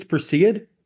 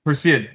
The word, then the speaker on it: Perseid: I was going to ask you about, I've had my pronunciation corrected on this. I believe it's Perseid. – Perseid, yeah.